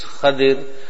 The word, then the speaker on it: Khadir: This is the place of the meeting when they came there, in the narrations, Khidr, or as we said, the proper pronunciation is Khadir, was resting, he was lying down.